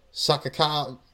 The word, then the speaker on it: Sakazaki: Oh man, like she's fighting Yuka Sakazaki.